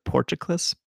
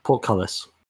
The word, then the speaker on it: portcullis: Okay, that's good, because I also don't know how to pronounce the word porticlus. Porticlus? – Portcullis.